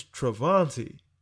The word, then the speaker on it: Travanti: And so, my name is Travanti. You mean to tell me I was a stutterbox and I had to also re-say my name? – Right. – As a stuttering, stammering kid to someone who doesn't even hear my name properly or doesn't want to, and so they call me Deontay, and just they blackify that shit as much as possible.